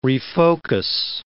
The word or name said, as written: refocus